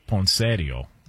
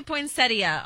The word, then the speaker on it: poinsettia: Well, the in-laws would call it poinsettio. – Oh gosh. – Okay. What up? You know what I'm saying? Can we get the proper pronunciation on this? – It does say poinsettia online.